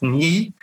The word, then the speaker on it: Nhi: And I actually said it right on the podcast because it's N-H-I, so it's Nhi.